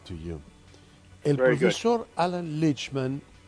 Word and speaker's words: Lichtman: Lichtman.